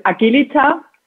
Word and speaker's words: Agilita: Yeah, it's in the German, it's a hard G, so it's Agilita.